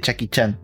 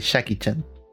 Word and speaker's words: Jackie Chan: Bueno, ya que vos hiciste la pausa para eso, eh, yo me estoy seguro que no se dice Chucky Chan y se dice Jackie Chan.